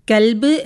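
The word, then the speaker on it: കൊൽബ് (قلب): അതുപോലെ ക എന്ന അക്ഷരത്തിന് 2 ഉച്ചാരണങ്ങൾ വരുന്നു. 1 ക, 1 ക്വ. കൽബ് എന്ന് പറയുമ്പോൾ നായ എന്നർത്ഥം വരുന്നു. എന്നാൽ കൊൽബ് എന്ന് വരുമ്പോൾ അതിന് ഹൃദയം എന്നാണ് അർത്ഥം വരുന്നത്.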